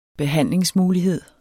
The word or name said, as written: behandlingsmulighed